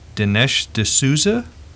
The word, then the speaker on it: Dinesh D'Souza: And this is by author, if I'm pronouncing this correctly, Dinesh D'Souza.